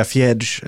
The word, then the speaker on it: Fred: Så om det är fred nu i United, skulle du säga fjärds eller fred? – Fred skulle jag säga.